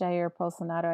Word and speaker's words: Jair Bolsonaro: And actually, the truth is in global politics, because the president of Brazil right now, I think it's Jair Bolsonaro. I think that's how you say his name. – He has, sounds right.